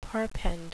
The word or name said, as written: perpend